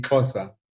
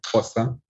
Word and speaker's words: Xhosa: So that would be Xhosa. It's kind of a click. You click your tongue along the inside of your teeth, like Xhosa.